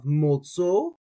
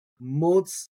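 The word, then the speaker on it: מצאו: So a lot of people, right, they leave that aleph silent. So in that case, they have motzo. When I like to pronounce my aleph, so motz-a-o, motz-a-o, motz-a-o.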